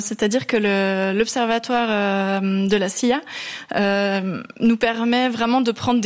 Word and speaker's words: La Silla: Et vous êtes même parti en 2012 au Chili pour 19 nuits d'observation sur le télescope suisse à la SILLA. – À la SIA.